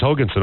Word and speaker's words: Hogason: And Zach Hogason, is that how you pronounce his name? – Yes, it is. – Oh, yes, Hogason. Okay. I got it right. What do you know? It's a miracle. I actually got a pronunciation right because it's a little bit different than the normal way you spell Hogason.